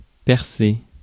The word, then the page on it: drill: American English; [drɪl]